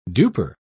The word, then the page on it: duper: British English